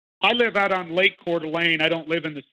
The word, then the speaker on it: Coeur d'Alene: But I have a buddy who moved recently to, I think it's called Coeur d'Alene, if I'm not mistaken. If I'm pronouncing it correctly. And I know that Coeur d'Alene, that's where I'm at.